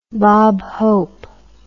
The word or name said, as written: Bob Hope